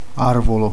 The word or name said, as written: albero